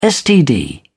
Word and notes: std: British English

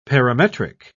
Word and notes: parametric: British English

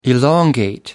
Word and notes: elongate: American English

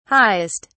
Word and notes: highest: British English

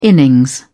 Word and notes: innings: British English

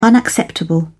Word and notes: unacceptable: British English

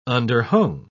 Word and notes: underhung: British English

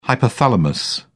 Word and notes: hypothalamus: British English

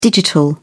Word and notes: digital: British English

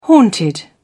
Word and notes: haunted: British English

